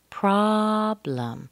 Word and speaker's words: problem: And then problem, two syllables, problem. Make sure that O is very short. Pra, pra. And what I mean by that, a short O sounds like ah, pra. Problem. The second syllable has a schwa. Blum, blum. So, pra is very long and strong. Blum is very weak.